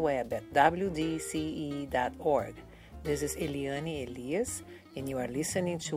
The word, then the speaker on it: Eliane Elias: Eliane Elias is the way I pronounce it, and she has a beautiful accent.